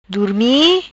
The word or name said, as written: Durmi